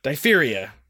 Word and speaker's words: diphtheria: Like dife and then ha- or yeah, I did it right. Okay? Diphtheria. There's a diphtheria outbreak in Nome. Diphtheria is an upper respiratory tract illness that causes the neck to get swollen.